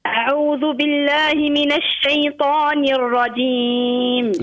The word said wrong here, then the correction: الرجيم: لا مش الرجيم. شو الرجيم؟ الرجيم بين الجيم. – أعوذ بالله من الشيطان الرجيم. – علاش اشبيه الجيم تنطق فيها هكاك ترى كيفاش تعاود الرجيم.